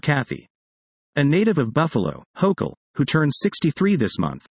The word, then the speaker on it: Hochul: Hochul, which rhymes with local, was among the first politicians to call out a recent spate of anti-Semitism in the state, in May 2019.